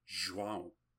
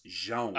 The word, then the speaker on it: João: In Portuguese it's João. But really? Me? Really it's, no, for John, it's João. I guess they say João, but João.